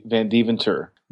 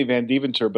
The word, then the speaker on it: Vandeventer: You know what's funny is that in Santa Barbara, where I'm at now and where I lived for several years, there's a doctor by the same last name. He pronounces it Vandeventer. I say Vandeventer, but actually, if you go back to Holland, which is where we're from, they say Vandeventer. – Nice. – So well, we're both wrong. I say Vandeventer, but it's a family tradition of pronouncing it wrong.